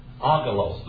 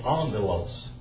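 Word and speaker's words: angelos (ἄγγελος): It's agalos. Agalos, but that's not the way you say it. You say it angelos.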